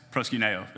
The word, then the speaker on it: proskuneo: And I looked up how to pronounce that, and it's proskuneo.